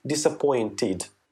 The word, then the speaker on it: disappointed: Действительно, когда наши слова прилагательные заканчиваются и не только прилагательные, глаголы, например, заканчиваются на звуке «т» или «д», действительно, при добавлении окончания «иди» мы произносим его как «ид». Например, interest – это интерес, заинтересованный будет interested. Study – учить, изученный будет studied. Disappoint – разочаровывать, разочарованный, соответственно, будет disappointed.